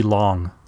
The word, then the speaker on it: Lang: And as I understand it, you often hear Fritz Lang, and I may bounce back to that, but I think the actual pronunciation is supposed to be Long.